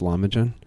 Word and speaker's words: Lahmacun: The Lahmacun, it's Lamuchans, Lahmacun. Remember the C, you know, it's a good idea to understand the phonetics of the language. The C is pronounced as a J, and anytime you have a little tail on an S or a C, it's pronounced as a CH or an SH.